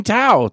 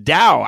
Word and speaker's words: Tao: What do you mean Tao? Tao or Dao? I thought it was pronounced Dao. I don't think you pronounce it Tao with a T. It's more like the Dao.